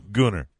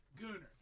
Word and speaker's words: Gunner: Proper pronunciation, Gunner. – Gunner.